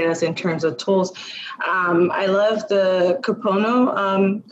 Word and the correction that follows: Ho'oponopono: component. Am I saying that right? – Ho'oponopono. – Ho'oponopono, thank you.